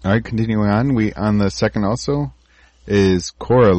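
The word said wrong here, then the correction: Korora: Because there's an extra syllable in there that Cora is just missing. – But if you move your head kind of fast, it sort of blends in together because it's just O-R-O-R. Corora.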